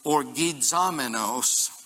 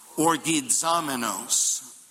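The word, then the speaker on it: Orgizomenos (ὀργιζόμενος): And the Greek geek moment, the word that's used here is orgizomenos. Wow, that's a mouthful. Try it with me. Orgizomenos. The accent is on the Z-O.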